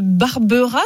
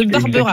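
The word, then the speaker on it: Barberaz: Vous nous appelez de Savoie, de Barbera, je crois qu'on ne prononce pas le Z dans la région. De Barbera.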